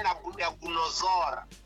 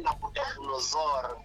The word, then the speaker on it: Nabucodonosor: E o rei é Nabucodonosor. Rei Nabucodonosor. Vi que estava difícil de pronunciar.